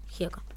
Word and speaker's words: Gieco: Y después cantamos una canción que se llama, todos juntos, que se llama El País de la Libertad, de León Gieco. No sé cómo se pronuncia. Gieco.